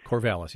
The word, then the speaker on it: Corvallis: Is that how it's pronounced? – Corvallis, yeah.